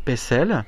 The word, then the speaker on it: Pexel: Ça s'écrit Pexel, mais on devait prononcer plutôt Pessel.